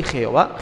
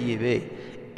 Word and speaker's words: Yahvé: Yahvé es la verdadera, la auténtica pronunciación del Dios de Israel. No es ni Jehová, Jehová es un nombre más falso que Judas, ese no es el verdadero nombre de Dios.